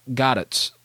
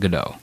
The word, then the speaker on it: Gadot: With Gal Gadot. By the way, that's how you pronounce it, Gadot. – Okay. – Her, Gadot. There we go, Gadot. Not Godot. Gadot.